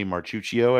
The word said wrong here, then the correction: Mauricio: I believe is how you say that. – Mauricio. – Ah, okay. Wow. – Okay. You're close. Close enough.